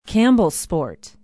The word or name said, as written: campbellsport